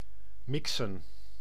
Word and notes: mélanger: France; [me.lɑ̃.ʒe]

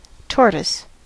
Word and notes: tortoise: American English; [ˈtɔɹ.təs]

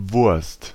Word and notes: Wurst: /vʊʁst/; /vʊrst/

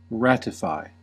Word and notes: ratify: [ˈɹæt.ə.ˌfaɪ]; /ˈrætɪfaɪ/; /ˈrætəfaɪ/

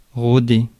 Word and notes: rôder: France; IPA: /ʁo.de/

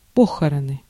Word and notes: похороны: /ˈpoxərənɨ/; pohorony

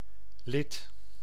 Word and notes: lid: /lɪd/

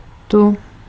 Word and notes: här: /hæːr/; /hɛːr/

